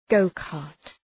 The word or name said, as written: go-cart